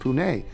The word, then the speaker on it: Pune: The only Indian city that I think I might be close to Pune is Pune, which is pronounced Pune.